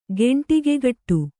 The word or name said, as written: ಗೆಂಟಿಗೆಗಟ್ಟು